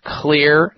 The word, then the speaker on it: Xlear: Clear Nasal Spray, and the Clear is spelled X-L-E-A-R. The X is for xylitol.